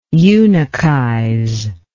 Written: eunuchize

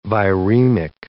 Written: viremic

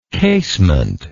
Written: casemented

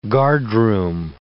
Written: guardroom

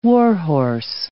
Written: warhorse

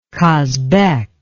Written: kasbek

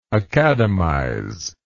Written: academize

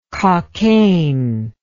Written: cockayne

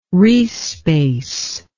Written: riesz space

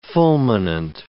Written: fulminant

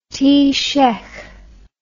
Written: taoiseach

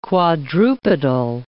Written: quadrupedal

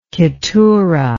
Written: keturah

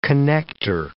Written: connecter

